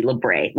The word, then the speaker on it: labret: You, you, it's spelled with a T. Labret.